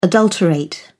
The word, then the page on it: adulterate: British English